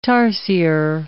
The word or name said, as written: tarsier